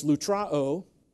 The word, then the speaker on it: lutrao: Now here's the third one, the third word for, and it's pronounced lutrao, okay? The last O is long, so it's lutrao.